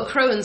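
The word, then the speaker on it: Akroan: They're running four Defiant Strike, four Akron, or I can't pronounce it, or Arcoen. – Arkham's Astrolabe. – No, not that one. – Oh. Oh, a Crow and Skyguard. – Thank you.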